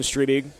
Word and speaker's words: Striebig: The 2 big ones certainly on Wisconsin's board still out there, Owen Striebig, am I saying that correctly? – Yep.